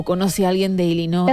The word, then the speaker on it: Illinois: Illinois. No Illinois. Bien. Sigue, no sé si lo habéis escuchado, yo sí lo he escuchado. Dice que pronuncia Illinois.